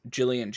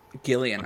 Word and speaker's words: Gillian: As well as Jillian Jacobs. She's kind of the narrator of the second movie. – I'm sorry, it's Gillian? – Gillian. – The hard G.